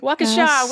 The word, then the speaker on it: Waukesha: I'm pretty excited that I get to say Waukesha. Jessica in Waukesha, Wisconsin. – Waukesha, we know how to say it now.